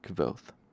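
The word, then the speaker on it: Kvothe: It might be T-H-E. Hang on, gotta go back and look. Oh yeah, it's T-H-E. K-V-O-T-H-E. Kvoth. It's, I've read this book before, so I know later on it specifies that you pronounce it like the word quoth.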